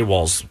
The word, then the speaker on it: Walz: But you just say Walz. Walz, same way.